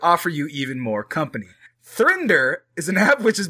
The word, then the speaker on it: 3nder: How do you pronounce it? Thrender launched to help people find more than one partner at once. So, it's the number 3, N-D-E-R, for those of you already interested.